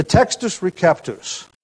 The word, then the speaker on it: Textus Receptus: The Textus Receptus, often pronounced with a soft C, even though there is no soft C in Latin.